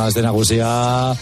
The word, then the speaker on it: Aste Nagusia: Feliz Aste Nagusia. ¿Se dice así? ¿Eh? – Sí, ¿no? ¿Qué pasa? Buenas tardes. Sí, sí. Bueno, buenas tardes. Dice aquí, bueno, Aste Nagusia.